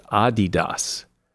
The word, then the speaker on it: Adidas: Everybody says Adidas, the sportswear line, but it's actually pronounced Adidas.